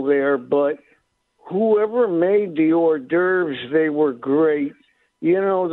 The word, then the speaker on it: hors d'oeuvres: Those are hors d'oeuvres, right? – Oh, horse deovers, yes.